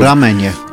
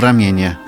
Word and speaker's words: ramenie: O ramenie. – Tak jest. Co ja powiedziałem? – O ramienie.